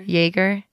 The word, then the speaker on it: Jager: Jager.